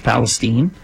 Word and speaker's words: Palestine: Looks like it's Palestine, but it's pronounced Palestine.